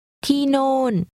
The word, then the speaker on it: ที่โน่น: ที่โน่นโน่นโน่นที่ที่โน่นที่โน่น This is pronounced with two falling tones.